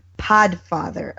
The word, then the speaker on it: Podfather: She says, please tell it's pronounced Podfather of Zombies.